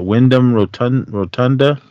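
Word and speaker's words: Windham Rotunda: Was it Wyndham Rotunda? Is that yes. – How you say it? – Yes.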